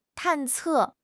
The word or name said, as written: 探测